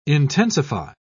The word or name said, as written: intensify